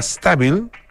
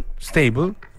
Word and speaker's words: Staybl: Em, tenemos un tiempito, sí, una, cosita, una cosita corta, eh, que es bien interesante, una aplicación gratuita que se llama Stable, perdón, Stable, Stable, que se escribe raro, S-T-A-Y-B-L, Stable, y que usa ciertas tecnologías existentes en el iPad para facilitar su uso a las personas que tienen temblores involuntarios, ¿ah?